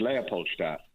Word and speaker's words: Leopoldstadt: And we've all been pronouncing the name of this play wrong. – Yes, I think it's been said as, I mean, Tom's such a trickster because, you know, it's Leopoldstadt is the district.